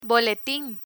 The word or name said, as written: boletin